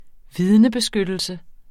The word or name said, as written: vidnebeskyttelse